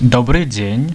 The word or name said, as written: dobry dzien